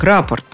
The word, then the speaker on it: рапорт: Ударение в нем по правилам ставят именно так – рапорт. Это подтверждают все словари ударений. Никаких других вариантов они не приводят. Что же касается множественного числа, то и там ударение неизменно остается на первом слоге.